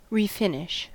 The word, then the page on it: refinish: American English